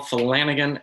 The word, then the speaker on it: Flanagan: Facebook is just Paul Flanagan, FLANAGAN.